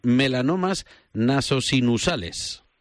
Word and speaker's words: melanomas nasosinusales: Son 5 años de tu vida intensamente dedicados a estudiar un análisis genético de pacientes con, a ver si lo digo bien, melanomas nasosinusales. – Perfectamente dicho.